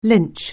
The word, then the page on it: lynch: anglais